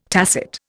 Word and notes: tacit: Indian

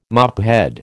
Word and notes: mophead: American English

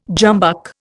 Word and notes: jumbuck: Indian

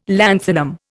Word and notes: lanthanum: Indian

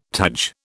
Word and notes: taj: British English

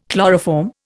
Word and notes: chloroform: Indian

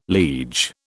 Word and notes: liege: British English

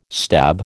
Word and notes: stab: American English

